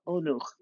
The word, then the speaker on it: Onuch: Lexicon pointed out to me I was pronouncing the other author's last name wrong. It's Olga Onuch, which reminds me yet again, as I've said many times, that it's much easier to pronounce Ukrainian when it's written in Ukrainian.